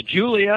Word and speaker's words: Giulia: Pronounce it for me. I'm terrible with these pronunciations. – Well, there's the Giulia, and then there's the Giulia Quadrifoglio.